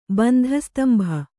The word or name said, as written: ಬಂಧಸ್ತಂಭ